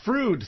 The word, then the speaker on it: Freud: We do our shows at the Froude Theater. It's spelled Freud, but it's pronounced Froude because they had a falling out.